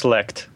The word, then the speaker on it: Leicht: It's L-E-I-C-H-T. – I think it's Leicht.